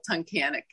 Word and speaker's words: Tunkhannock: Tunkhannock? – So I live in Northeast Pennsylvania, and the town is called Tunkhannock.